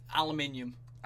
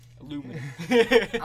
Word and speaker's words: Aluminium: I really enjoyed that. Aluminium. – Aluminium? – Yeah. – The Americans can't say it right. – Aluminium.